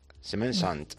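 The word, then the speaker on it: Semaine Sainte: La Semaine Santa. – Semaine Santa. ¿Santa? – Saint. – Saint. Semaine Saint.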